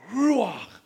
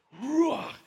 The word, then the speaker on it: ruach: The Hebrew word is ruach, and it's pronounced this way, ruach, ruach. And when you say it, it sounds like you're breathing.